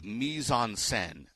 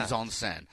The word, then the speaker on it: Mise en Scène: He's going with Mise en Scène, French pronunciation. – Ooh, I like that. – Mise en Scène. So okay. We're going with that as well, Mise en Scène.